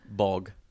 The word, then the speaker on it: Bolg: Bolg. – So you don't really pronounce the L. – Bolg.